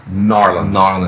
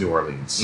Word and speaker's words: New Orleans: I think the proper way to say it is New Orleans. But then if you're from there or on the outskirts, you say New Orleans.